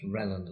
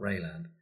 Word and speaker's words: Wreyland: Yeah spelt W R E Y land, but I'm sure it's pronounced Reland and not Rayland.